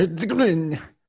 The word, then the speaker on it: Herzegovina: Bosnia and Herzegovina.